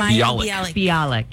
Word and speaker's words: Bialik: But how do you say her last name? – Bialik, yeah.